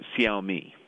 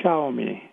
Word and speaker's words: Xiaomi: I like to pronounce it Xiaomi. – Xiaomi.